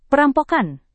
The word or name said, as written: perampokan